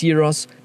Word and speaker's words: gyros: No, they're called... it's spelled G-Y-R-O-S. Some of them write it with a G. Some of them write it with a Y. It's Gyros, it's Euros.